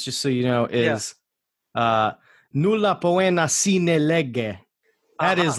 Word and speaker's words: nulla poena: Nulla poena.